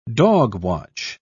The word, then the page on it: dogwatch: British English